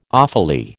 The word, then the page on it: awfully: American English